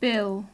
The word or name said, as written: bill